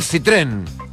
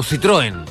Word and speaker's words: Citroën: Miren, lo voy a pronunciar de las dos formas, el Citroën o Citroën, la marca del vehículo.